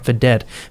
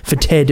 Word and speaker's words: Fetid: 30 is Concealer, Fetid, F-E-T-E-D. I didn't say that right, did I? Fetid is what I meant to say, not Fedet. Fetid, Fetid. And at number 27, Sugar Brown, Poor Lazarus is the name of the album.